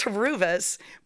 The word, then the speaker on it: Horuvis: When I meet Greeks, they're like, "Oh, Horuvis." Like, I don't know how to pronounce it. I'm like, even Greek people get it wrong. – No, they are like saying I get it wrong. – Oh, you. – So it's Horuvis.